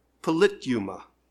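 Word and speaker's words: politeuma: Just write P-O-L-I-T-E. And then you add U-M-A. So it's polite and then U-M-A. And you pronounce it polituma.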